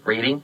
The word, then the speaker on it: Reading: Reading, Pennsylvania, Reading, get it? – I got it. – Long E, okay,